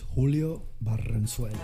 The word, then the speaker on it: Julio Barrenzuela: My name is Julio Barrenzuela. – There it is. See how he rolled that R?